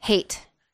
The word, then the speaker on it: Haight: You say H Street? – Hate. – Hate Street? – Oh, yeah, hate. It's spelled H-A-I-G-H-T.